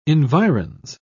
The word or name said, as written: environs